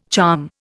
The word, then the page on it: charm: Indian